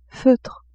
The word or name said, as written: feutre